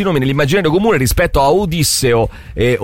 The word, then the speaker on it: Odisseo: Invece per quanto riguarda Nolan, c'è un articolo sul Post che spiega che innanzitutto bisognerebbe dire Odisseo e non Ulisse, cioè bisognerebbe dire Odisseo. Con l'accento sulla prima I, anche se è più diffusa la pronuncia greca Odisseo.